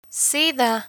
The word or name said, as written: sida